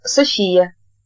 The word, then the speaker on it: София: «Меня зовут София». Она сделала ударение на последнем слоге и очень гордо произнесла свое имя.